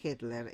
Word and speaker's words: Hettler: El comisario, según tengo entendido, el nombre, el apellido sería Hettler. – Ajá, ahí está, Hettler. Es complicado de escribir, me parece. Pero bueno, esa es la pronunciación.